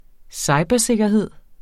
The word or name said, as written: cybersikkerhed